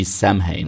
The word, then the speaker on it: Samhain: deswegen Samhain, ja, das schreibt man eigentlich mit einem M und einem H, und einem H also S-A-M-H-A-I-N, okay? Wie Samhain, mhm.